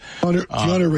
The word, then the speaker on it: Keanu: Am I saying his name right? Keanu Reeves? – Keanu Reeves. – Keanu. Yeah.